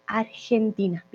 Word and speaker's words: Argentina: Entonces, ya saben, Argentina con una I y esa G se pronuncia G. Argentina. Viene de aquí como una J, es una G fuerte.